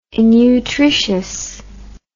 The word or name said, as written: innutritious